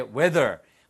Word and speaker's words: whether: 그런데 바로 뒤에 whether, 뭐뭐일지 아닐지 이 표현 알아두셔요. W-E-A-T-H-E-R은 날씨란 뜻이고요. W-H-E-T-H-E-R, 발음은 똑같아요. Whether, whether, 아시겠죠?